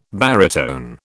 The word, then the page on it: baritone: British English